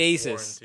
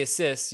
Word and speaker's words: Asus: When I call the phone line, they say Asus. – They don't know how to pronounce their own name. – I've always said Asus, and they say Asus. – Asus sounds better. – It just sounds normal, but then again, like Pegasus, but you don't want to say Asus.